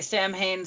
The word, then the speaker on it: Samhain: It's spelt S-A-M-H-A-I-N. You want to say Samhain. – You want to say Samhain so bad. – So badly, but it's a Gaelic word or Celtic word, depending on your definition. And it's Samhain. That's how it's pronounced. If you've ever wondered, if you've only ever seen it spelled, it's Samhain.